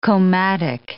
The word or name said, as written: komatik